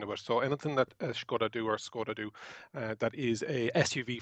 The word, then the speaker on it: Skoda: First, we're going to pronounce Skoda correctly. So it's Skoda.